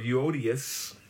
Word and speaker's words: Euodias: Two women by the name of Euodias and Syntyche. That's how you pronounce it, Philippians 4.